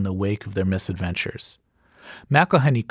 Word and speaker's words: McElhenney: It's McElhenney, McElhenney.